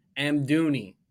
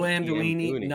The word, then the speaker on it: Amdouni: I just wanted to pronounce this guy's name correctly because I just finally learned it. Zeki Amdouni. No Amdouini, nothing. Amdouni opened his account for Burnley.